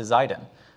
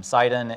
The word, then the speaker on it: Zidon: Now you'll see in your Bible that this is spelled with a Z. It's also spelled with an S. It's just on who's sort of speaking it. Sidon is one language, Zidon is another language, it's the same place.